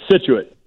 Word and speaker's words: Scituate: Scituate.